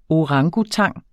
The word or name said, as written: orangutang